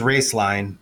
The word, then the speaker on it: Roeslein: And so the name is Raceline, but it's spelled Roeslein and then alternative energy.